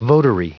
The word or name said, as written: votary